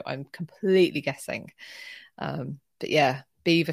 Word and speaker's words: Belvoir: It's just mental that it's spelt Belvoir and then they make us pronounce it Beaver.